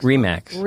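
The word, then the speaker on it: RE/MAX: REMAX.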